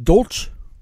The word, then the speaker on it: Dolch: Is it Dolch? – It's Dolch, yes. – Dolch, okay.